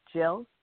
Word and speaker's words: Jill: Hi, this is Jill. – Okay, can you say your name again? – It's Jill, like Jack and Jill.